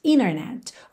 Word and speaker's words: internet: na palavra internet, que muitas pessoas pronunciam internet. Ou seja, esse NT, internet, a gente tira o T e liga no N, internet, ok?